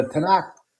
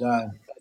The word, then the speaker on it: Tanakh: And it shows a bit of the JPS, which is a Hebrew to English, Tanaka, which is T-A-N-A-K-H. The Tanakh is pronounced Tanakh, or Tanakh. Also, is the Mikra, which is M-I-K-R-A, is the canon of the Hebrew Bible.